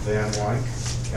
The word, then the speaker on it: Van Wyk: My name is Jack Van Wyk, capital V as in Victory, A-N, capital W-Y-K.